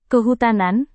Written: kehutanan

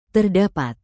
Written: terdapat

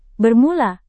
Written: bermula

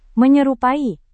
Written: menyerupai

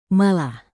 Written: malah

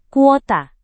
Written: kuota

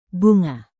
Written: bunga

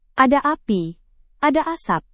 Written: ada api ada asap

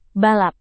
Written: balap

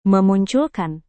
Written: memunculkan

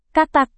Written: katak

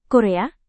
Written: korea

